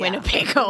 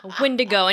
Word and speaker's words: Windigo: Windigo. – Windigo. Yeah. – Winnebago. Oh my God. That's embarrassing. – Like, I combined that with a dingo. A Wingo. – You know. All right. Windigo.